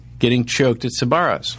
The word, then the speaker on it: Sbarro: I mean, that sounds like Sparrows pronounced incorrectly. – Sbarro's. There's Sparrows. A little bit of a B in there.